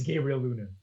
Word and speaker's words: Gabriel Luna: Before we move on, it's not Diego, it's the Luna part that was right. – So what, Luna? – It's Gabriel Luna. – Gabriel Luna. – Gabriel. – Say it like, yeah, how it is.